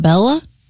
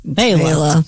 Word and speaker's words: Bela: Born in 1958, the great banjo player, Mr. Fleck, first name B-E-L-A. – B-E-L-A. It's not Bella? – It's Bela. – Bela.